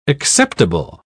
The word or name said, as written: acceptable